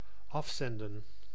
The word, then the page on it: afzenden: /ˈɑfˌsɛndə(n)/